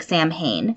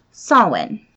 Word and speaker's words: Samhain: It's S-A-M-H-I-A-I-N. It looks like Samhain, but in Gaelic, it's actually pronounced Samhain.